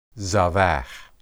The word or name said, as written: zabah